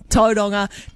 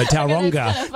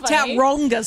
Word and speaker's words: Tauranga: Your name sounds like you would pronounce Tauranga. Tauranga. – No, Tauranga. – Tauranga, sorry.